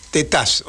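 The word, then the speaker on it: Tetaz: Martín Tetás, o sea, el acento en la última, en la A, digamos, para, para no confundirnos.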